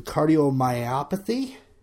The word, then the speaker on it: cardiomyopathy: Robert actually died from cardiomyopathy.